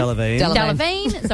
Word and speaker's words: Delevingne: How do you say her last name? – Delevingne.